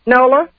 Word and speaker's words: Nola: Please pronounce it correctly for us. – Nola. – Lola? – N-O-L-A. – Nola. – My board operator spelled it L-I-L-L-A, so you have to excuse me.